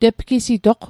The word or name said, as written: tepgisitoq